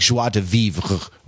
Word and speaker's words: joie de vivre: You're supposed to kind of hairball out the final R-E, so it's like joie de vivre. A French Canadian professor of mine once said you pronounce the R but not the E, so like joie de vivre or Sartre.